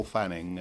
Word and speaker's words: Fanning: It's Paul Fanning, and it's an Irish name, a Southern Irish name, and it's spelled F-A-N-N-I-N-G.